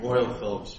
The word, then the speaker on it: Royal Philips: Royal Philips, Your Honor. – Royal Philips, I can say that, versus Quectel Wireless.